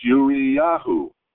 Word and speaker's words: Uriahu: And it can also be written Uriahu, pronounced Uriyahu,